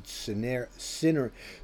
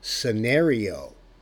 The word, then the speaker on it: Synereo: It's spelled Synereo, and I pronounced it Synereo the other day. And actually, the way they pronounce it, because I've been doing a little research on it, and they had some pretty good videos on it, it's called Synereo.